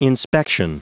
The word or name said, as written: inspection